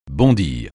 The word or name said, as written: bondir